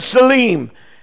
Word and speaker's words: tselem: The word image in the Bible, in Hebrew, it's the word selim, T-S-E-L-E-M, but you don't pronounce the T, okay?